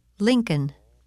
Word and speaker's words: Lincoln: The letter L is silent in the following words. Read them and repeat each one during the pause. – Half, halves, salve, could, should, would, Lincoln.